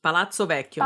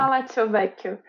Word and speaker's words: Palazzo Vecchio: Eu gosto particularmente de uma obra dele que é a Judite decapitando o Holofernes, que tá lá no Palácio Vecchio. Falei errado, Palácio Vecchio. – Palazzo Vecchio. – Palazzo Vecchio, lá em Florença.